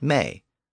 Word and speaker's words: me: The Anglo-Saxons pronounced it may, so the vowel shifts changed it to modern me.